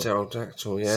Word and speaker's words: pterodactyl: Oh, yeah, pterodactyl, yeah.